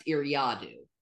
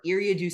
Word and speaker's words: Eriadu: I know it is pronounced Eriadu. I don't care. Eriadu sounds better.